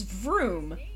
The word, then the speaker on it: Vroom: So his name is Vroom, but it would be said the same as Broom. It's pronounced identically because they're the same letter.